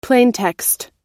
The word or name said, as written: plaintext